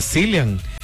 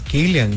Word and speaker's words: Cillian: Yo le decía Cillian, pero resulta que también es Killian. Él dice, es Killian Murphy, el ganador del Oscar.